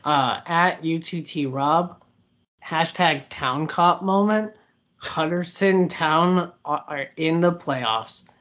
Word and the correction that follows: Huddersfield Town: Oh, I'm glad you butchered it. – Huddersfield Town, but yes.